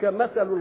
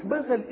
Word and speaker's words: مثل: كلمة مثل إذا سمعتها الميم والثاء واللام بتنطق كما يأتي، إما أن تنطقها مثل وإما أن تنطقها مثل. مثل بكسر الميم وسكون الثاء كده، ومثل بفتح الميم وفتح الثاء.